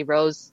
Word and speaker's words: Roos: It's actually pronounced a little bit differently. But when I speak English, I can't even pronounce my own name the Dutch way. So I just say Rose the English way. And so everything is Yoga with Rose, Plant with Rose is all R-O-O-S.